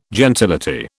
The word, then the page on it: gentility: British English